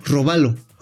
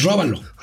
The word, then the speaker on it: robalo: Robalo. – Híjoles, aguas que no lleven la cartera. O que no pongan acento en robalo, porque entonces róbalo. Róbalo.